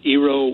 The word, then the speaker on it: Eero: I am considering getting an EERO, I guess it's pronounced Eero, Wi-Fi system.